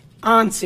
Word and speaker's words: Anse: The Hatfields lived in West Virginia, and they were led by William Anderson Hatfield, or William Anse, they called him, I think. I think that's how it's pronounced. It's A-N-S-E.